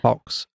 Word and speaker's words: Fawkes: It's celebrated on November fifth, and I hope that I pronounced it right. Okay, yeah, Fox.